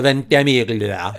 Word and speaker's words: Ventimiglia: No, you have to say it like Lady Gaga would. – Milo Ventimiglia.